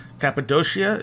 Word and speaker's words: Cappadocia: And around that same region in From the Ashes of Angels, you talk about Cappadocia, is that how you say it? – Yeah, that's just to the west of this region.